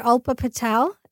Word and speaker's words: Alpa Patel: I'm under Alpa Patel. – Can you spell it for A-L-P-A. Last name is Patel, P-A-T-E-L.